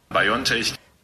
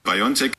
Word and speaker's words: BioNTech: Und da jetzt die Frage, Köhler, wie spricht man dieses Unternehmen aus, das diesen Impfstoff hergestellt hat? Ist es, wie Bundesgesundheitsminister Spahn sagt, A. BioNTech. Oder B. BioNTech. Ähm, also ganz klar B.